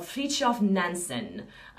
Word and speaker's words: Fridtjof Nansen: And there was a young man called Fridtjof Nansen,